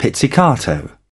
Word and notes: pizzicato: British English

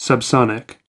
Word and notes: subsonic: American English

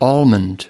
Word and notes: almond: American English

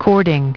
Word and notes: cording: British English

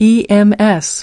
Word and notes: ems: American English

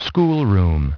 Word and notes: schoolroom: British English

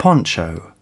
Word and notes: poncho: British English

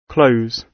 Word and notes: close: British English

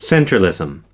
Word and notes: centralism: American English

